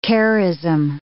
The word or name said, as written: charism